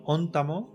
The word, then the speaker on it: Ontamo: N'hi ha que dir Ontamo, val? perquè és com ho pronuncien, no sé en el doblatge com ho hauran pronunciat, però els, és Balandil i Ontamo.